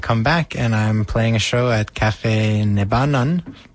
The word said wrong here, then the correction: Nebenan: I don't know how to pronounce it. – Nebenan? – Nebenan. Thank you.